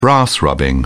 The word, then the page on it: brass rubbing: British English